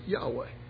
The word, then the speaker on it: Yahweh: Some will say Yahweh. They'll put in a couple of the vowels of their own and pronounce it Yahweh.